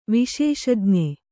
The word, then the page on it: ವಿಶೇಷಜ್ಞ: viśeṣajña